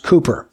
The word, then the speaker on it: Cowper: His name is spelled C-O-W-P-E-R, but instead of Cowper, it's actually pronounced Cooper.